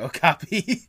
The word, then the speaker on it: Okapi: Okapi. I think I said Okapi or something like that.